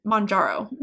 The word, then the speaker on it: Mounjaro: and then Mounjaro. Did I pronounce that correctly? – Yep.